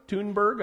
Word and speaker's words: Thunberg: Thunberg, I guess it is, actually. You don't pronounce the H in German.